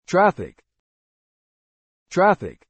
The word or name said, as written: traffic